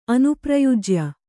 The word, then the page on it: ಅನುಪ್ರಯುಜ್ಯ: kannada